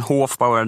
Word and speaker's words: Hofbauer: Bröderna Hofbauer, säger man Hofbauer? – Ja, det, det stämmer. Många säger Hofbauer, men Hofbauer, det, det är rätt, så det stämmer.